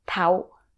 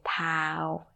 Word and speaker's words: เท้า: But if you were to read as it's written, it's tau. It's like short vowel, right? – Yeah. – But no one say that. – Mm. – We just say tau, pronounced like a long vowel.